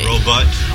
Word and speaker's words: robot: Or robot is the way it should be. Robot. – From robot. – Like a short O. – Worker. – Not like a short U. Row, row, row you. Furthermore, it's iambic. Robot.